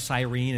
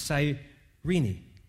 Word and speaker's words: Cyrene: Now, I always thought that was Cyrene until this week when I looked up in a Bible dictionary. I said, I've been mispronouncing it my whole life. It's Cyrene. And Simon was from there.